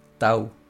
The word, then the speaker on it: Tau: Se escribe como una X y se lee Tau.